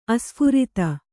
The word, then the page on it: ಆಸ್ಫುರಿತ: āsphurita